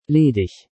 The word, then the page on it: ledig: German